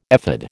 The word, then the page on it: ephod: American English